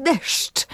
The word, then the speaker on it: deszcz: I teraz, skoro w polszczyźnie występuje ubezdźwięcznienie końcowych spółgłosek, to od początku swojego istnienia wyraz deżdż był skazany na niepowodzenie. – Wymawiany był jako deszcz.